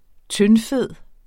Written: tyndfed

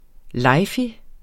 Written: lejfig

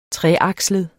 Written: treakslet

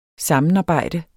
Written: sammenarbejde

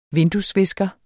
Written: vinduesvisker

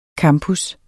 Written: CAMPUS

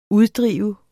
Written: uddrive

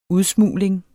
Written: udsmugling

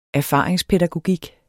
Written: erfaringspædagogik

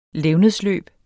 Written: levnedsløb

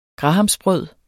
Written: grahamsbrød

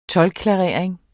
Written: toldklarering

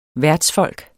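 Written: værtsfolk

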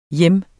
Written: hjem

